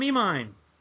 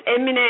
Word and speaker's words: Emine: It's close. It's Emine, actually. It's just like Emine, the chocolate. – Okay. – Yes, it's Emine.